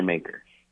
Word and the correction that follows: Makar: Maker. Kale Maker. – Maker. – Or Makar. It's technically Kale Makar. – That's the pronunciation I've been hearing is Makar.